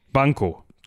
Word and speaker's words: Banquo: Bekommt das aber zusammen mit Banco? Banco? B-A-N-Q-U-O. Banco.